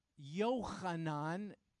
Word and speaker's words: Yohanan: And the second mentioned in chapter 40 is a fellow named Yohanan, spelled Yohanan. Johanan is probably how you'll see it, but that his name is pronounced differently.